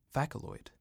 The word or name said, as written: Phaceloid